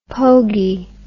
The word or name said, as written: pogie